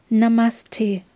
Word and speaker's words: Namaste: Es ist kein, kein T oder kein Stay drin, sondern es ist einfach Namaste. Die Betonung liegt auch eher auf der zweiten Silbe.